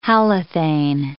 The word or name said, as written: halothane